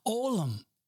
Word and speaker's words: olam: It is pronounced poorly by me, olam.